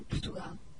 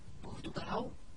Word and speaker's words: Portugal: Como podem ver, uma das características principais da pronúncia do português do Brasil é que quando as palavras acabam em L, lê-se ao. E agora vou dizer algumas palavras como os brasileiros: Portugal, legal, banal. Em português de Portugal seria Portugal, legal e banal.